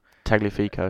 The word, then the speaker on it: Tagliafico: Tagliafico.